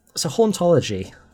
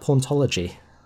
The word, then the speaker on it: hauntology: So, how would you sum up hauntology? – The way it's introduced in Derrida is you've got to you've got to pronounce it in a French accent because you've got to go ontology. So it's a concept, right?